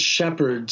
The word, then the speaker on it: Shepherd: And it's Philip with one L, and Shepherd spelled exactly the way the guy who looks after sheep is spelled, S-H-E-P-H-E-R-D.